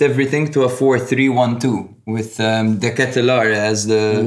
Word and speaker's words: De Ketelaere: But speaking of dangerous players, when Milan made those substitutions, one man that significantly fucking stood out is Charles De Ketelaere, by the way. – That's how you say it. – It's De Ketelaere, apparently.